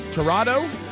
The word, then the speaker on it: Tirado: Bubba, I guess the name is Diane Tirado. It's spelled T-I-R-A-D-O.